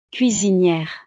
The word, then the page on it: cuisiniere: frances